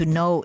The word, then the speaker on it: know: Il verbo è irregolare, to know, new, known, e come ogni altra parola inglese che inizia con K e N si pronuncia come se la K non ci fosse.